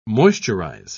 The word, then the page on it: moisturise: British English